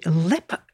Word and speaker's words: Leap: And I know it's spelt Leap Castle, but the Irish way of saying it is apparently Lepp Castle. – Like leprechaun.